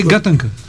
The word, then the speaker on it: гатанка: Не е ли гатанка? Как е ударението? – Двойно е. И гатанка, двойно. И гатанка. А-а-а. Може да си както ти върви.